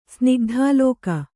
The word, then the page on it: ಸ್ನಿಗ್ಧಾಲೋಕ: Kannada